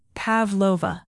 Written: pavlova

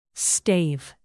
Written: stave

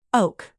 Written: oak

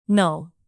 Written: null

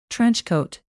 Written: trenchcoat

